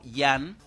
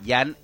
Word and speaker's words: Jan: Jan en polaco se escribe con J, ¿sí? Jan, ¿sí? Porque Pero no suena la J. – la J suena como I en polaco, ¿no? – Ah.